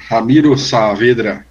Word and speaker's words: Ramiro Saavedra: Como é que seria a pronúncia em bom espanhol, você que é uruguaio? – É isso mesmo. Ramiro Saavedra.